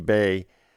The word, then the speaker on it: Bey: Also, right before the top of the hour, we heard more Andy Bay. His last name is spelled B-E-Y.